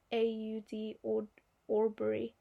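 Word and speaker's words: Ahmaud Arbery: That's how you pronounce his name, is Ahmaud Aubrey.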